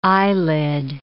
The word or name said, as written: eyelid